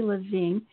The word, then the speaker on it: Levin: And to help us today is Nancy Levine. Oh, my goodness, Nancy, how do you say your last name? It's actually Levin.